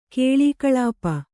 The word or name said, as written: ಕಲಾಕಲಾಪ